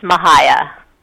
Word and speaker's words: Mahaya: It's a really hard name to say, and most people mispronounce it. The correct pronunciation is Mahaya.